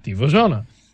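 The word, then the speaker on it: Dziwożona: Divajana.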